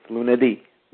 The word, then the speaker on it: lunedì: Notice most of them will have the accent on the I, the last syllable or the last letter. So it's lunedì. You have this stronger emphasis will be towards the on that last syllable.